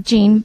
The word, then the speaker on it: Jean: It is by Jean LaFrance, J-E-A-N looks like Jean, but I think it's pronounced Jean, LaFrance.